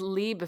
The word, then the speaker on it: Loeb: How do you pronounce that? L-O-E-B. – I think it's Lieb if it's German, right? – God, yes.